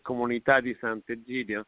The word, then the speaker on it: Comunità di Sant'Egidio: Uh, in English is Giles, St. Giles, but mm-hmm. in Italian it's Comunità di Sant'Egidio. And when I joined,